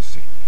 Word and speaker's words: Say: Talora io per 30 anni l'ho chiamato Say, ma pare che i francesi lo pronuncino Say.